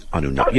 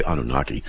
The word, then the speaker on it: Anunnaki: And plus it's fun saying Anunnaki. You know, I tell people how to say it properly. You don't say Anunnaki, you say Anunnaki. You really got to get into it.